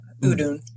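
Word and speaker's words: Udûn: Yeah, Udun.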